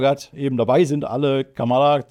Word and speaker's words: Kamala: Kamala Harris, Kamala, hat Martin eben noch gesagt, wäre eigentlich die richtige Aussprache, hätte sie jetzt letztens erklärt. Kamala, egal, auf jeden Fall, Kamala sage ich eigentlich lieber, das geht irgendwie besser über die Zunge, wie auch immer, das werden wir uns bestimmt alle jetzt mal angewöhnen irgendwie.